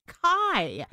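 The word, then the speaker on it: Khai: It's Kai. Mm. Now, I hope I'm actually saying it right. It's K-H-A-I.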